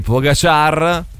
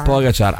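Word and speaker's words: Pogačar: Tadej Pogacar, non si dice così. – Pogacar ha vinto il Tour de France.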